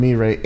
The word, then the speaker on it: Mirai: You can search on Mirai. M-I-R-A-I is how it's spelled. – Okay. Got it.